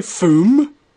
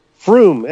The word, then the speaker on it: Frome: Did you say Foom? – Froome, F-R-O-M-E. They pronounce it Froome.